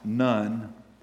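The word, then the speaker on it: none (ن): Christians in Iraq and Syria are being marked um, by ISIS with the Arabic letter N, which is none. It's pronounced none.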